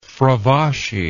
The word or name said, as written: fravashi